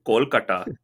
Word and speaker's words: Kolkata: What about What about Kolkata? Why'd you say it like that? What about Kolkata? – That's the way I've always heard Bengali say it. Kolkata.